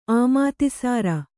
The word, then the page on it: ಆಮಾತಿಸಾರ: Kannada